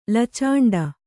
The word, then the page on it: ಲಚಂಡ: lacaṇḍa